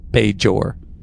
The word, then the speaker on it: Bajor: She has the hard J. Bajor.